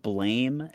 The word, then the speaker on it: Blame!: You know, when you start up the game, and Ryan, I need to ask you if you know what the manga, it's spelled Blame! Exclamation point, but it's pronounced I do know of it. – Blam. I'm just going to say Blame, because Blom sounds stupid.